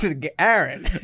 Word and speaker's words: Garron: You said it wrong. It's Garron.